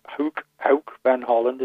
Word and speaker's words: Hoek van Holland: Then we're down to the ferry port and the Hoek van Holland, as they pronounce it, across to Harwich.